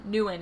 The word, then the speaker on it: Nguyen: Nguyen is how it's pronounced. Yeah. And it's, Vietnamese.